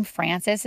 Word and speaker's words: Frances: My name, Frances, is spelled F-R-A-N-C-E-S. It actually means free. F-R-E-E, free.